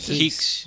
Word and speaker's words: Higgs: Higgs.